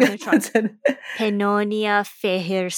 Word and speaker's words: Fehér: Pannonia Feher Satin. – Pannonia Feher Satin.